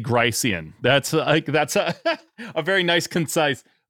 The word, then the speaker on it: Gricean: So how do you say, is it Grecian? How do you say the maxims? – H. Paul Grice. So I pronounce Grice. It Gricean maxims from the Stanford Gricean maxims.